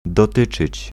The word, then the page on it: concerner: France; [kɔ̃.sɛʁ.ne]